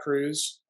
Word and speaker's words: Kruse: You know, Dr. Jack Kruse. My last name is spelled K-R-U-S-E.